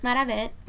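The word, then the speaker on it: Marabet: My name is Maravet Morales, and I go by she or her. And I'm on social media as Maravet 510. And it's spelled M-A-R-A-B as in blue, E-T, and 510 for Oakland.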